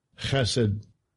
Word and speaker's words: Chesed: And it's the word in the Hebrew, H-E-S-E-D. Can you play that thing? Listen to how he pronounces it in this thing, because I'm not going to do it this way. – Strong's H, 2617. Chesed. Chesed.